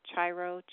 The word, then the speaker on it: Cheiro: That's what you learned as a teenager from, I always forget how to pronounce his name. It's Chiro, C-H-E-I-R-O.